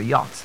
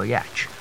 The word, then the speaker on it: yacht: That's why it's pronounced the yacht, not a yatch.